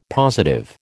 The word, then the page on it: positive: American English